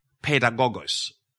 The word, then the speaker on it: paidagogos: The Greek word that is translated tutor or disciplinarian or schoolmaster, depending on your translations, is actually pedagogos. If you're writing something down, it's spelled P-A-I-D-A-G-O-G-O-S.